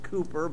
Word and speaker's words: Cowper: It's spelled C-O-W-P-E-R, but I have studied this. It's pronounced Cooper, but you can pronounce it Cowper if you want.